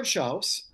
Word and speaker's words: Schauss: It's Fred Schauss. – You said it right.